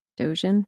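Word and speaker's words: Dōgen: It says, it's by, I don't know how to say this, Dogen, D-O-G-E-N. How would you say that? – Dogen.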